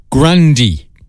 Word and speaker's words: Grande: Ariana Grande, We Can't Be Friends. Did you know her surname is actually Grandy? Yeah, we've been mispronouncing it, saying it wrong for years.